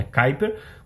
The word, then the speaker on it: Kuiper: Primeiro vamos falar dos planos da Kuiper. É, a gente falava errado, tá? Então, Teletime costumava chamar de Kuiper, não é Kuiper. O nome da constelação da Amazon é Kuiper, como o cinturão de Kuiper, né?